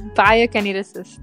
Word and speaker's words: biokineticist: It's biokineticist.